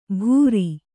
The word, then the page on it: ಭೂರಿ: bhūri